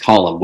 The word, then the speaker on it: Colm: It's spelled Colm, but it's pronounced Colm, which again is far more ridiculous than this language needs to be.